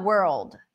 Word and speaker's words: world: Okay, so you're having the R, the L. That's why we have the dark L, right? World. – Yeah. Which is actually easier because if you put the light L, it really messes up the pronunciation because it gets you to, it makes it sound like world.